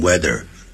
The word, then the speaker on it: whether: 所以现在呢美国中西部绝大部分 75% 以上的人，大家念这个字的时候都是念成 weather，跟天气一样的。